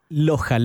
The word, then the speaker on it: Loja: Tenemos una preguntita que nos llegó desde Loja, Ecuador, o Loja, Ecuador, no sé cómo se pronuncia. – Loja, Loja.